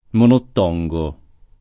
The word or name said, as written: monottongare